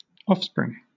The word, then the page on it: offspring: British English